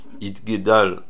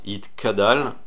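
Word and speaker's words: Itgadal (יתגדל): Il faut bien prononcer le guimel de Itgadal pour ne pas que ça s'entende Itkadal, qui est un langage de Oref, ça veut dire l'arrière de la tête.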